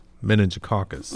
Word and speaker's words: meningococcus: You said meningococcus. Mm-hmm. Did I say it right? Mm-hmm.